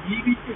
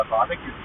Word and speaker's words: バーベキュー: であの書き表すときは BBQ って書くけど、それ実際に BBQ って発音しないので、読むときはバーベキューって言うよみたいな感じで、困ります。